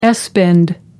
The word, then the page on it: s bend: American English